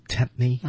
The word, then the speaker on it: tempt: Don't tempt me.